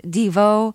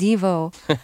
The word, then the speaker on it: Devo: I have to ask you, all these years I've been listening to your music, I've been pronouncing your band's name Devo, and now I hear you alternately saying Devo and Devo. So which is it? – It's both. That's the beauty of Devo.